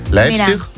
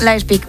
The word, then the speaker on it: Leipzig: Leipzig. – Leipzig, ¿ves? – Leipzig, muy bien.